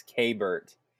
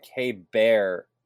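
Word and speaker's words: Keibert: How do we pronounce Mr. Ruiz's first name, to your understanding? – To my understanding, it's K-Bert. – Okay. – But I think K-Bear might also be correct.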